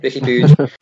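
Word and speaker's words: Ricky Puig: But that's what we think of Ricky Pooge. Anyway, tell your mates that's how you say it, Ricky Pooge.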